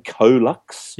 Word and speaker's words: CoeLux: I'm saying Colux. – Yep. – COELUX. – Yep.